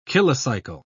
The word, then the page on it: kilocycle: British English